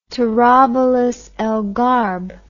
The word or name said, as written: Tarabulus el Gharb